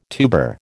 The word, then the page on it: tuber: American English